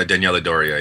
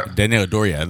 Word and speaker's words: Daniela Doria: Is that how you say her name? – Uh, Dan- I believe Dan- Daniela so Diora. Oh, Dan- uh, Daniela Doria. Yeah, Daniela Doria. There you go, Doria, not Diora.